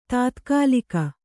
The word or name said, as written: ತಾತ್ಕಾಲಿಕ